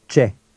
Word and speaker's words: Ce: Ce. – Repeat it aloud after him. Always repeat aloud after the speaker. – Ce. Ce. – Notice the sound of the C-E combination.